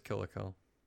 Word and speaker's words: Kill la Kill: Is it Kill la Kill? – Yep, just Kill la Kill.